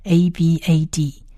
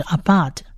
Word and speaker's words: abad: 如果用英文来描写这个字的话，它其实它的发音是 abad，就是 abad。